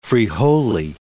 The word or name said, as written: frijole